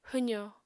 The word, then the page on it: hwzò: apatani